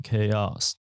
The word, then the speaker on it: chaos: 这个字比较特别一点，要 C H 开头，C H A O S，可是它是念成 chaos。